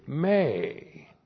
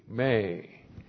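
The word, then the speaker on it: may (μή): Point two, we have with Memeomai the negative may. M, long E, may.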